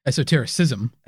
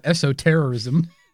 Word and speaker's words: esotericism: Now, before we mentioned that Western esotericism, esotericism is a big tent term for lots of knowledge and schools of thought that exist outside the mainstream. Now, esotericism. – Esotericism. – Esotericism. – Not esoterrorism.